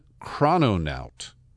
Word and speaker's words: chrononaut: chrononaut. That's spelled C-H-R-O-N-O-N-A-U-T. It's like astronaut and chrono. Chrono refers to time.